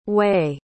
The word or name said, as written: wy